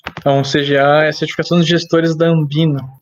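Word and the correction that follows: ANBIMA: É Ambima, sempre falo Ambina.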